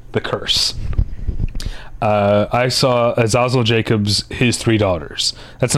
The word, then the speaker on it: Azazel: One of the fun things about going to festivals with the directors are there um, is uh, learning how to pronounce their names because they get introduced and they're there, and it's Azazel.